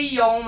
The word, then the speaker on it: John: Ai, meu nome é Elisa Beatriz de Oliveira John, que se pronuncia Ion, mas eu falo John pra facilitar. J-O-H-N é um sobrenome alemão, tá? – Não e tem...